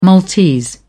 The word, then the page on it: maltese: British English